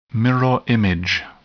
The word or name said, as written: mirror image